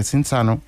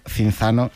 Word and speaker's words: Cinzano: Cinzano en 1940. Y este apellido, pronunciado italiano, quizá no nos suene tanto, pero si decimos Cinzano, nos va a sonar a todos muchísimo más.